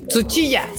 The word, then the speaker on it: Tsuchiya: Kenichi Tsuchida. Tsuchiya, Tsuchiya. Ah, como Ana Suchilla.